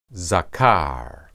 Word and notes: zakar: Hebrew

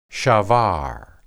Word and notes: shabar: hebrew

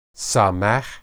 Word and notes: samah: hebrew

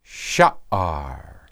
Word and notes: shaar: Hebrew

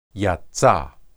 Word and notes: yatsa: Hebrew

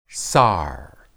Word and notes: sar: Hebrew

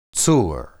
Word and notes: tsur: hebrew

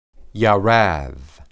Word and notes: yarad: Hebrew

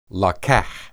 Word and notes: laqah: Hebrew

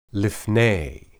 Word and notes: lipney: Hebrew

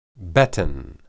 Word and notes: beten: hebrew

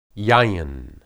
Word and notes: yayin: hebrew

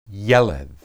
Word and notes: yeled: Hebrew

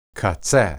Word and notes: qatseh: Hebrew

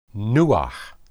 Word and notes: nuah: hebrew

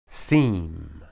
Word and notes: sim: Hebrew

